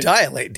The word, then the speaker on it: dilate: I just said dilate, didn't I? – You've used three syllables, but there's only two if you say it right.